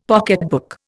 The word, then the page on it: pocketbook: Indian